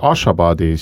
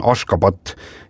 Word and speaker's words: Ashgabat: Jaa, selle pealinna tänapäevane õige kirjapilt ja hääldus on Ashgabat ja siis 1991. aastal nimetati siis paljud linnad ja asulad selles riigis ümber ja kui vanasti tunti seda ikkagi tõepoolest selle Ashgabati nimel, siis Ashgabat on tänaseks see nii-öelda nagu õige hääldus ja kirjapilt, see S-il on mingisugune väike siuke konks all ja Ashgabat täpselt niimoodi ta ongi.